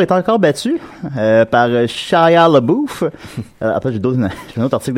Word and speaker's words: LaBeouf: C'est LaBeouf.